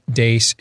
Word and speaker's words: Deace: Follow us on Twitter at Steve Dace Show. Last name is spelled D-E-A-C-E.